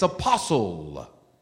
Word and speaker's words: apostle: It's not apostles, it's apostle. The T is silent. Apostle.